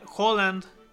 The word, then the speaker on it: Haaland: Pero bueno, Sevilla 1-0 contra el Rennes, ya lo decías, Dortmund vence al Zenit 2-0, Haaland,